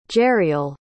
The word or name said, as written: jeriel